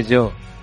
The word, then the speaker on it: Gio: No se dice Gio, se dice Yo. – Yo. – En italiano, Gio es yo.